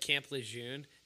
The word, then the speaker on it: Camp Lejeune: Like Camp Lejeune.